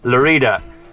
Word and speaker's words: Lleida: I'm in a place called Lerida. It's spelt L-L-E-I-D-A, but everybody says Lerida.